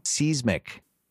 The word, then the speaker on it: seismic: This is the first time we hear him pronounce this word wrong. – Seismic. – Is that when the earth has a seizure? – It's almost as if someone wrote that word down for him, and he just looked at it and said, looks like seas. – Well, here's how the Cambridge Dictionary would have us pronounce it.